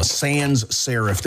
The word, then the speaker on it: sans serif: And so, like, Google and LinkedIn, they use a sans serif. Did I say that right, Pancake? – Yes, sir. – Font. – You sure did. Absolutely.